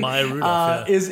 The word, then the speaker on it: Maya Rudolph: Wow, that's and Maya Rudolph's or Minnie, who? Am I pronouncing it all correctly? – Yeah, Maya Rudolph.